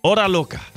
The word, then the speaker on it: Hora Loca: Hora Loca. Did I say that right? – Yeah.